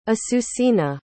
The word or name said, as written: asucena